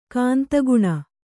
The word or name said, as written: ಕಾಂತಗುಣ